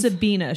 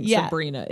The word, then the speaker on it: Sabina: It is Sabina. She's not mispronouncing Sabrina. It is Sabina.